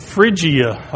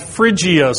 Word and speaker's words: Phrygia: This area geographically is called Phrygia, I believe is the way you pronounce that. Michael might be able to correct some of these later on. But I believe that's called Phrygia.